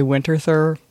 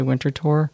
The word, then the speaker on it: Winterthur: But this is the museum that is always difficult for me to pronounce because you want to say Winterthur, but it isn't. It's actually Winterthur.